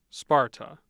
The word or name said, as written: Sparta